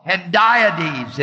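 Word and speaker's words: hendiadys: The figure of speech is called Hendiades. It's spelled H-A-H-E-N-D-I-A-D-Y-S.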